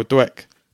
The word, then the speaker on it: Dweck: Carol Dweck.